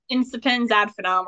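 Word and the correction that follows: Incipiens ad finem: Incipiens ad finem.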